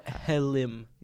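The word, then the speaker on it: hellim: Or halim. – Yeah, as the Turks call it. That's the right pronunciation.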